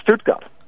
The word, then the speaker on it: Stuttgart: It's Stuttgart.